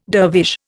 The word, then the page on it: dervish: Indian